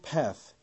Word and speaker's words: path: 그럴 때 PATH가 미국식은 path 이렇게 했죠. 그런데 영국식으로 읽었습니다. path.